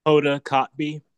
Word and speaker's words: Hoda Kotb: The B is a letter and it's literally pronounced Kotb.